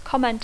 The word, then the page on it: comment: English